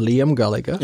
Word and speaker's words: Liam Gallagher: Habe ich es richtig ausgesprochen? Liam Gallagher. – Ja, das ist richtig.